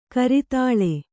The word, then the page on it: ಕರತಾಲ: karatāla; Kannada